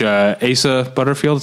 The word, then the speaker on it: Asa Butterfield: Moving on, I saw a movie that's coming out in, I think next month, called The House of Tomorrow, in which Asa Butterfield, is that how you say his name? – Yep.